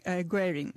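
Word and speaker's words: Guerin: Ποια ήταν η αντίδραση της αστυνομίας της Βικτόριας σε αυτή την παρέτηση του κυρίου Γκέριν, αν προφέρω σωστά το όνομά του? – Κάπως έτσι, Γκέριν ή Γκέριν.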